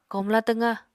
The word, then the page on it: komla twqá: Apatani